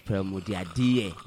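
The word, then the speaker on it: Pearl Modiadie: Pearl Mudiad. – Yes. Pearl Mudiadie. You must say it right.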